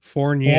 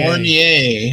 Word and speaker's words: Fournier: Alice Cooper, Vincent, what the hell is his last, how's his last name pronounced? Fournier. – Fournier, huh? As in fornification? – Well, go figure.